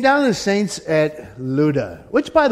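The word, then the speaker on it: Lydda: That's pronounced Luda. It looks like Lydia. There's no I in there. Uh, the Y is like a U. And so it's Luda.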